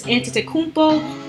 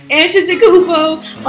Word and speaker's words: Antetokounmpo: And speaking about babies, also congrats to Giannis Antetokounmpo. Whoa, I said it right that time. Antetokounmpo on a new baby boy and his girlfriend.